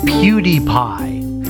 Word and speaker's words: PewDiePie: Okay, I'm being told that I'm supposed to say the name PewDiePie, like cutie pie, but it's spelled P-E-W, so like a pew in a church, that's how it's pronounced.